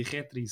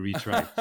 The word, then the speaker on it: Retry: E eu dizia Retri, sabia lá que era Retry.